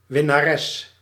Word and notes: vainqueur: France; [vɛ̃.kœʁ]

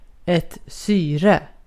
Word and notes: syre: /ˈsyːˌrɛ/

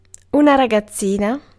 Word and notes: teenager: American English; /ˈtiːn.ˌeɪ.dʒə(ɹ)/

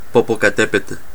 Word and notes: Popocatépetl: Chile; /po.po.ka.ˈte.pe.tɬ/

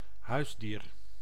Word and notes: animal domestique: /a.ni.mal dɔ.mɛs.tik/

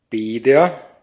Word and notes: Bäder: [ˈbɛːdɐ]

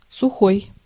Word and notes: land: American English; IPA: [lænd]

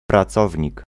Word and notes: employé: France; [ɑ̃.plwa.je]

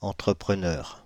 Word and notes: entrepreneur: /ɑ̃.tʁə.pʁə.nœʁ/

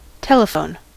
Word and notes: telephone: American English; /ˈtɛləfoʊn/

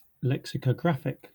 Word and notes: lexicographic: /ˌleksɪkəˈɡɹæfɪk/

